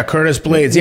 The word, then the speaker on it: Blaydes: I thought it was, uh, I thought it was, am I saying his name right? Blaydes? Uh, yeah, Curtis Blaydes. Yeah, yeah I'm pretty sure yeah.